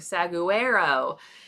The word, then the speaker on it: saguaro: If you were to see it, it is spelled S-A-G-U-A-R-O. And most people, when I ask and I show them that word without saying it first, it's saguaro or saguaro. It's saguaro.